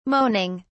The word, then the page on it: moaning: British English